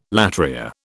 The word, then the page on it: latria: British English